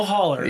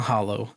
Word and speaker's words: holler: A little holler. – Yeah, that's right. And that's how you say it. It's not hollow.